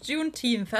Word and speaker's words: Juneteenth: Äh, Juneteenth. Jetzt habe ich es richtig ausgesprochen, glaube ich. Juneteenth.